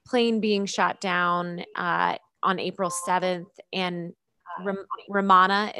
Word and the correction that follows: Habyarimana: is that how you pronounce the name? – Habyarimana. – Okay.